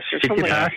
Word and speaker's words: Tsitsipas: That's his 19th Grand Slam event that he's won, and he beat Stefanos Tsitsipas from Greece. – Tsitsipas.